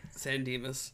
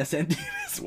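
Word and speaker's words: San Dimas: Saint-Denis, or whatever it's called. However you pronounce it. – San Demas. – Yeah, San Demas. Wow, I suck at that.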